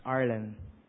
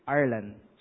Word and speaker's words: Arlan: It's Arlen. – Who? – Arlen. – Spell it. – A-R-L-A-N.